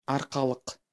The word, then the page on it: Арқалық: ɑɾqɑɫɯq; Kazakh